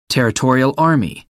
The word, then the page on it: territorial army: American English